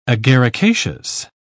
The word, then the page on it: agaricaceous: British English